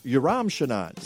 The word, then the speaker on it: Yoram Shanan: And am I pronouncing your name right, Yoram Shanan? – Yoram Shanan. – Yoram Shanan.